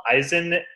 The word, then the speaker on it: Eizan: They plainly pronounce it in Japanese as Eizan.